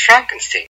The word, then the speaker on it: Frankenstein: Frankenstein, you're putting me on. – No, it's pronounced Frankenstein.